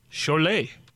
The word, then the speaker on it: Cholet: Cholet.